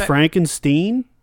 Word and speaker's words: Frankenstein: Yeah, they used dead people's body parts to create Frankenstein. – So with the guns, so the guns. – It's actually pronounced Frankenstein.